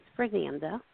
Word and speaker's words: Fernanda: My name is Fernanda. – How do you spell that? – F-E-R-N-A-N-D-A. – F-E-R. – Okay. – F-E-R-N-A-N-Z-A? – D.